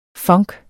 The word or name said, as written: funk